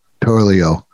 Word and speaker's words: Turturro: John Turturro.